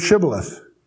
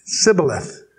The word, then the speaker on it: shibboleth: If you recall in Judges and Gideon, they wanted to know who was faithful, and they had him pronounce the word shibboleth. And if they were from the other side, they couldn't pronounce it, Sibboleth.